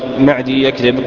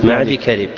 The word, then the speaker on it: معدي كرب: ليس معدي يكذب، مقدام بن معدي كذب، ابن معدي كذب، تنطق تكتب موصولة وتنطق مفصولة، ليس معدي يكذب إنما معدي كذب.